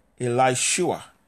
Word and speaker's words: Elisha: So we see that Elisha, there is a contracted or a shortened spelling for what we would pronounce Elishua.